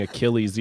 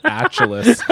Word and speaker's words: Achilles: Oh, this guy really could have won $1 million, and instead of saying Achilles, he pronounced it Achilles.